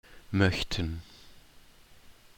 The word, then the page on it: möchten: German